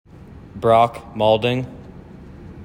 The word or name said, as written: brock maulding